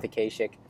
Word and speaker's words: Akashic: I've heard it pronounced different ways. I'm going to go with Akashic.